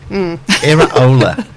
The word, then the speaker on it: Iraola: And this is how you pronounce it. – Iraola. – What? – Iraola, it's a second name.